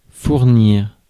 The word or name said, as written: afford